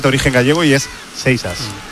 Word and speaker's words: Seixas: Exactamente os lo voy a decir. Lo que pasa es que luego cada uno lo dirá como quiera, pero es Seixas. Paul Seixas. – Seixas. – Así se pronuncia, Seixas.